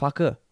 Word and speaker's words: Farquhar: Even though I think there's been more and more literature that's come out that says that William Farquhar, which is the correct way of pronouncing... No, yeah. I think it's pronounced Farquhar.